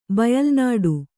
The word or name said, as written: ಬಯಲ್ನುಡಿ